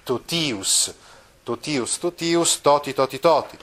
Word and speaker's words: totius: Ah, ecco, notate che questa desinenza in ius del genitivo ha la quantità lunga, pertanto si pronuncia così, totius, solius, ecco proprio totius è uno di quei casi del gruppo ti seguito da vocale ma accentato, si legge T.